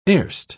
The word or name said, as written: erst